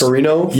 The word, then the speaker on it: Ferrigno: His name is Lou Ferrigno. Is that right? Am I pronouncing that right? – Yes. – Ferrigno? – Yes.